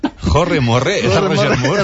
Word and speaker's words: Roger Moore: Me encontré con Jorge Merré, Jorge Morré, ¿cómo se dice? Jorge Morré. – ¿La conoces? – No, de nada. – Roger Moore. Roger Moore. El santo.